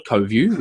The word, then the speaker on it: Coviu: And so now as an extension of that, we've got a video consulting platform inside the browser called CoView, which is spelt a little bit strangely, C-O-V-I-U. And so CoView